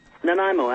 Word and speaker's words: Nanaimo: Nanaimo, actually.